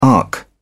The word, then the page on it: arc: British English